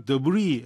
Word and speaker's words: debris: 여러분 이걸 debris S 발음 안 해요.